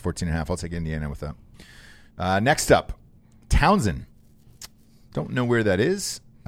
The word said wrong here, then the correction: Towson: Towson. Yeah, I think it's Towson. – Towson. – Towson. Oh, there's no N.